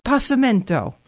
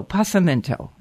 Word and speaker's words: Passimento: Schmooze spelled like school, S-C-H. Always remember that. It starts that way. Okay. The name of this wine is Passamento. P-A-S-S-I-M-E-N-T-O. Passamento.